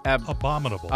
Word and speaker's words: Abominable: Like the abdominal. Is that, am I saying that right? Like Abominable.